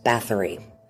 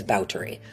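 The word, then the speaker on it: Báthory: You've probably heard it pronounced as Bathory. That's what most people say. But the proper period pronunciation is Bathory.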